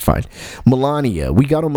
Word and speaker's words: Melania: Is it Melania or Melanie? Like, how do you say Mrs. Trump's name? – I don't care. – Okay, fine. I believe it's Melania. – Melania, because my daughter corrected me. So Melania.